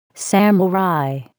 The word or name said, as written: samurai